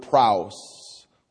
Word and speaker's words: praus: The Greek word in the lexicon for meek is the word praus. We spell it phonetically, P-R-A-U-S.